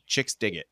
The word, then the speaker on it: Chixdiggit: Yeah, it's spelt CHIXDIGGIT. Chicks Dig It.